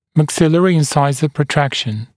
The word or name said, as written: maxillary insisor protraction MIP